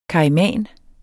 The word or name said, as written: KAIMAN